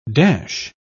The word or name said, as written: dash